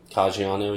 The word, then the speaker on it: Caggiano: What'd you say, Kajiano? – Yeah, I think that's how you say it. – Is that really how you say it? – Caggiano, Caggiano. – Caggiano. – Italiano. – Yeah. Very obviously Italian.